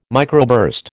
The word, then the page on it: microburst: American English